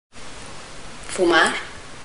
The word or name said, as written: smoking